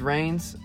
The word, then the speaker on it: Reigns: This is Reigns. Jordan Reigns is that would be the name I was given. And on here, I'm just going to go by Reigns. It's easier. It's one word, one syllable.